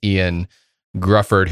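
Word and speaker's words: Ioan Gruffudd: Is it Ian Gruffudd? – It's the most Welsh spelling of it, but it's Ian Gruffudd, who, talking about Fantastic Four, he was Mr. Fantastic.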